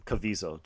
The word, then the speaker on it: Caviezel: Caviezel, Jim Caviezel.